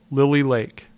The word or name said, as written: Lily Lake